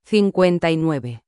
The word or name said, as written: 59